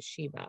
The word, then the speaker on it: Shiva: hope that I'm pronouncing that right, Shiva.